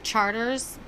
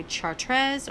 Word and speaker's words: Chartres: Yeah, like Charters. People would see the street name Charters and think maybe Chartres or some French Chartres, but no.